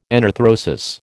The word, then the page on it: enarthrosis: American English